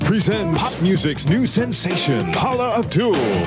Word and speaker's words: Paula Abdul: She was in on the party line, but he can't even say her name right. He said Paul Abdul.